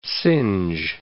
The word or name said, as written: singe